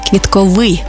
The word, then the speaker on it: квітковий: До речі, правильний наголос саме квітковий, бо стосується квітів.